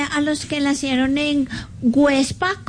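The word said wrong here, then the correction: Güepsa: Perdón, Huepsa. Websa. Con diéresis.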